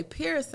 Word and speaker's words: Pierce: Firm, but um, every time somebody asks me my last name, I'll say Pierce, and they'll be like, "Huh?" And I'll be like, "Paul Pierce." I'm referring to the basketball player, uh-huh.